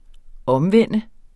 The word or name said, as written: omvende